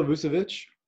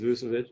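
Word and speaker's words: Vučević: Can you pronounce his name for me? – Nikola Vucevic. Yeah, or sorry, some people say Vucevic, or people say it different, but I think it's Vucevic.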